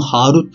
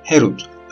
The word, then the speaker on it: Herut: Bunu Harut diye telaffuz etme. Herut, özgürlük diye telaffuz et.